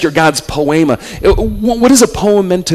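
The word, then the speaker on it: poema (ποίημα): The Greek word that stands behind it is pronounced poema. Can you say poema? Do you hear an English word tucked in there? Poem. You are God's poem.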